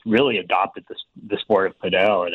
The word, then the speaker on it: padel: It's pronounced padel, even though some groups do call it paddle, but here in the Midwest with people playing platform tennis often call it paddle too. Proper way to pronounce it is padel. – P-A-D-E-L.